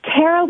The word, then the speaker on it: Tara: Well, it's Tara with a T, like Tuesday, but that's okay.